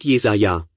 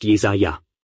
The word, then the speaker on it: Jesaja: Der Prophet Jesaja, oder du sagst Jesaja. Ich muss mich immer erklären. Ich bin in Schottland zur Schule gegangen und habe mein Postgraduiertenstudium gemacht, und so wurde Jesaja ausgesprochen. Und es ist mir einfach im Gedächtnis geblieben, also sage ich es immer noch so.